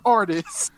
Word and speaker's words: Artis: He said artiste. Yeah, like artist. Nigga, his name is artist.